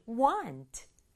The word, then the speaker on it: want: Want. Want. 에요. 이 W 다음에 A가 올 때는 A의 소리가 A나 A가 아니라 A 같은 소리가 나요. 그래서 want, want 이렇게 발음하면 되겠죠.